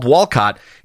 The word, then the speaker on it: Walcot: but the project is called Walcott, is spelled W-A-L-C-O-T, not like the street, but it's pronounced the same.